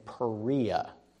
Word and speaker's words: Perea: Herod the not so great dies, and Herod Antipas is now the tetrarch of Galilee and Perea. It's not Persia, I mispronounced that earlier.